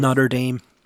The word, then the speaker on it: Notre Dame: But my big question now is, because I read up on a news story recently that Notre Dame, the University Notre Dame somewhere in USA, they have... – Notre Dame. They actually pronounce it Notre Dame. – Okay, Notre Dame.